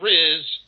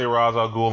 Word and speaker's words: Ra's al Ghul: Ra's al Ghul, not Ra's, not Riz. Ra's. – You know, I heard Neil Adams say Ra's al Ghul on the Kevin Smith podcast, so I'm okay with the Ra's al Ghul pronunciation because he created the fucking character. – Yeah, and that's how he says it.